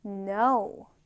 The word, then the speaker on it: know: K는 우리가 발음하지 않습니다. 그래서 no의 발음은 no예요. 그냥 N만 발음한다라고 생각하면 되고요. K는 묵음이 되는 겁니다.